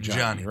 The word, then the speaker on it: Gianni: But, yeah, so it is at this point that in the film where Peter Neal and Gianni, or Gianni, it's like the Italian of Gianni, right? – Gianni. – Well, they don't do hard J's.